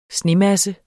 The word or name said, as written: SNEMASSE